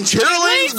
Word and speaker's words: chitterlings: Why is it that she says it chitterlings? – Chitterlings. Because that's how it's spelled. – Term for that chitlin food. – So white people say, "Are you bringing chitlins?" Black folks be like, "I'm bringing chitlins."